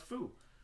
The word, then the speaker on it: pho: It's a dish. It's pronounced foo.